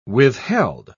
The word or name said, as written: withheld